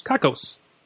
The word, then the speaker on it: Cockos: Kakkos.